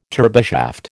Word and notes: turboshaft: American English

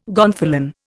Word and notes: gonfalon: Indian

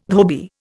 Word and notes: dhobi: Indian

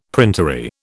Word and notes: printery: British English

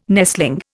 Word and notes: nestling: Indian

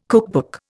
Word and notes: cookbook: Indian

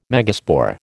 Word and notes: megaspore: American English

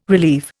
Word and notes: relieve: Indian

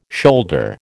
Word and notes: shoulder: American English

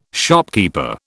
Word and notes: shopkeeper: British English

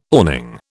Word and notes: awning: British English

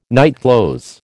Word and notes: nightclothes: American English

